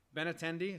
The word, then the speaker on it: Benintendi: be mean is Benintendi.